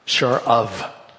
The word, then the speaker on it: Sharav: and it's called a Sharva, or excuse me, it's not a Sharva, it's a Sharav. That's how they pronounce it, I guess. It's S-H-A-R-A-V.